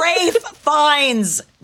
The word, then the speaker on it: Ralph Fiennes: It's Rafe Fiennes, not Ralph.